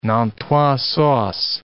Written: Nantua sauce

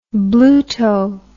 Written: bluto

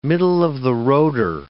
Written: middle-of-the-roader

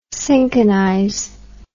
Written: cinchonize

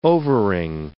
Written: overing

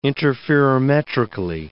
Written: interferometrically

